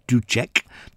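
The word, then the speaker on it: Dušek: Spohr and another composer called Dussek, or Ducek, to pronounce his name properly,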